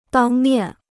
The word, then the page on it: 当面: dāng miàn